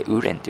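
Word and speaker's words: 无人: えこの 2 つ合わせてウーレンというふうに発音すると無人という意味になりますので、え今日はこちらの中国語とさせていただきます。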